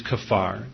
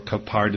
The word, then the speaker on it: kaphar: Well, the Hebrew verb translated to atone in the Scriptures is kafar, or kapar, depending on how you want to pronounce that, K-A-P-H-A-R, which means to cover over.